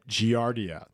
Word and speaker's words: Giardia: It's called Giardia.